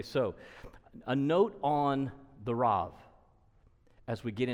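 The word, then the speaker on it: Rab: So you'd pronounce that B, R-A-B. You'd pronounce it more like a V, so Rav, something like that.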